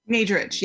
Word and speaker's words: Nadrich: Nadrich, yes.